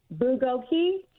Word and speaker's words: bulgogi: Bulgogi.